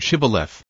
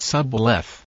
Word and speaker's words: Shibboleth: Whereupon, to determine if this were so, they were required to pronounce the word Shibboleth. This they could not do if they were Ephraimites, as they were unable to sound the H, speaking it Sabbaleth.